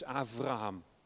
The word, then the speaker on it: Avraham: Die B spreek je uit als een V. Dat is bij Abraham is Avraham.